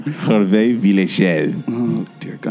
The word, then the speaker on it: Hervé Villechaize: Hervé Villechaize.